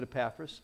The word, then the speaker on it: Epaphras: But this guy, his name was Epaphras, or I don't know how you pronounce it. I pronounce it Epaphras. And therefore, since I'm the preacher, I'm right.